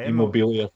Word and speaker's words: Immobile: Is it Immobile? – Yeah. Immobile.